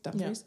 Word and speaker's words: Dumfries: First, he starts off in Dumfries, which is in, yes, that's how you pronounce it, right? – Dumfries yeah.